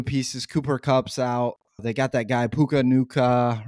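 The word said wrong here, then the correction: Puka Nacua: or Naku, right? And I'm going to butcher his name every time. – Puka Nakua.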